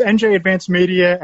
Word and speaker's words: NJ Advanced Media: Of course, Will, it's NJ Advanced Media, and of course the Star Ledger.